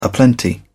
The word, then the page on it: aplenty: British English